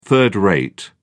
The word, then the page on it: third-rate: British English